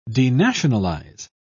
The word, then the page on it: denationalise: British English